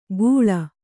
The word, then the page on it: ಗೂಳ: Kannada